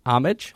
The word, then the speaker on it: homage: The H is silent. – Oh, Homage? – Try it again. – Homage? – Homage.